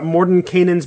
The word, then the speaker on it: Mordenkainen's: Today we are talking about Mordenkainen's Magnificent Emporium, or Mordenkainen's Magnificent Emporium. Those are the two pronunciations I will accept. Anything else is out. So don't even try it. Including Morty. We don't say Morty.